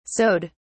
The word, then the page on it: sewed: British English